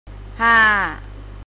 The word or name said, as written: ห้า